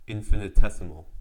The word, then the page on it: infinitesimal: American English